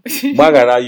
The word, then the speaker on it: Gbagharam: What did you hear me pronounce when I said my own Gbagharam? – Gbagharam, you said GH actually. – Yay! – Yes, I heard GH.